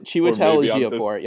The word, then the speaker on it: Chiwetel Ejiofor: Chiwetel Ejiofor? – Something like that. I'm sorry, dude. I can't pronounce your name. – Chiwetel Ejiofor. – Chiwetel Ejiofor.